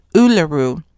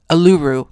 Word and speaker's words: Uluru: So I'm going to talk about that a little bit later, maybe even talk a little bit more about Uluru. How was I pronouncing it before? Uluru, I think. And they got me straight real quick. So before I go, I will make sure to have that down pat.